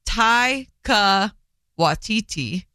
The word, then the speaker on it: Taika Waititi: And Rita Ora and her husband, Takiti. – I love him. – No, sorry. Taika Waititi. – Mm-hmm. – Taika Waititi. Sure.